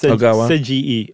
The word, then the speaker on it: Seiji: Ogawa, and it's Seiji. Any vowel you see in Japan just screams to be pronounced independently.